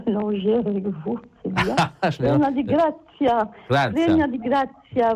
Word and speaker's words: piena di grazia: Ave Maria, piena de grazia.